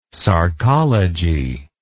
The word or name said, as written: sarcology